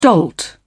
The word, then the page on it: dolt: British English